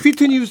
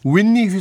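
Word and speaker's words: Whitney: 그리고 휘트니 휘스턴도 윈니 휘스턴이라고 불러주셔요. – 아 그래요? – 네, 윈니. – 아 윈니 아랫니 하는 자.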